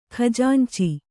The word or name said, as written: ಖಜಾಂಚಿ